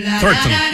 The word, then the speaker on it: Thornton: Este es un homenaje de esta audición deportiva a Melanie Thornton. – Thornton. Me gustó la pronunciación.